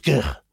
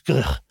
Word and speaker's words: Gwrych: G-W-R-Y-C-H. – And it was in... – pronounced Grych. – How do you say it? – Grych. – I'll say it. – Well, like the Scottish Loch. – Okay. – Loch, Grych.